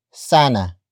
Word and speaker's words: sana: we have sana